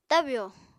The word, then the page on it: tabyò: Apatani